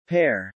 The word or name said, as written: pear